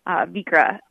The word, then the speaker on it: Vikre: So it's spelled V-I-K-R-E. Vikra,